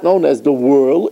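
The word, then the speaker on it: whorl: Now, there's a fancy word for it, it's called the whirl. By the way, that's the H is silent. It's W-H-O-R-L. But it's pronounced whirl.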